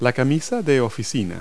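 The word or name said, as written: dress shirt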